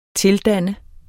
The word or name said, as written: tildanne